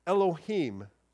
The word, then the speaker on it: Elohim: It's actually the word in Hebrew that we would pronounce Elohim.